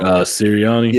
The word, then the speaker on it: Sirianni: Sirianni.